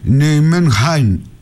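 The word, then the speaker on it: Nijmegen: Η Ολλανδική όμως λέει προφορά είναι Νιουμενχάιν, είναι κάπως βαθύ το χ, δεν μπορώ να το πετύχω.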